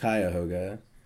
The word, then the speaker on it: Cuyahoga: That's Cuyahoga like the river, which is pronounced Cuyahoga, and DK as in Death Knight.